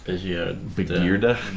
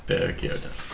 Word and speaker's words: Begierde: The we'll do a Stumfjord, Bernhard Begierde. That's right, it's German, it's hard G's. Bernhard Begierde.